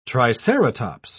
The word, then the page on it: triceratops: British English